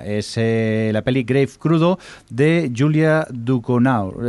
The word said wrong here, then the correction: Ducournau: ¿Esto cómo se pronuncia, eh, Javi, en francés? Duconau. – Eh, Ducournau. Ducournau.